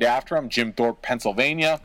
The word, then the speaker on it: Jim Thorpe: So anyways, Jim Thorpe.